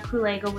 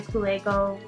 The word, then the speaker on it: Kulego: So IG is at Edwina Kulego, Facebook is Edwina Kulego. – Just spell that for people, just so they know. So Edwina is E-D-W-I-N-A, Kulego is K-U-L-E-G-O.